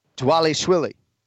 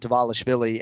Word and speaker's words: Dvalishvili: But going forward, yes, it is not the V sound. It is Merab Dvalishvili.